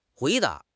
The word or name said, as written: hui2 da2